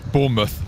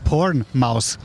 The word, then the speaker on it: Bournemouth: Hilf mir weiter. – Bournemouth. – Bournemouth. – Bournemouth. – Bournemouth. – Bournemouth. – Not porn mouse.